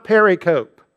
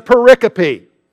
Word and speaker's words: pericope: By the way, don't make the mistake of mispronouncing this. It's not pericope. If you had just read that word and never heard it pronounced before, you might think it was pericope. You want to make that mistake. I made that mistake an hour ago. And fortunately, somebody corrected me in between services, and now I know the correct pronunciation. It's a pericope.